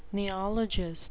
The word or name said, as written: neologist